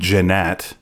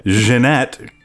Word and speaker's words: Jeanette: I've been saying Jeanette, like an American. Jeanette